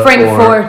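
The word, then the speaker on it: Frankfort: So is Lawrenceburg and Frankfurt, like, are they pronounced like they're spelled, or is there like a Loretta? – It's Frankfurt.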